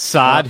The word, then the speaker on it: Saad: Yes, Saad, who had the game-tying goal.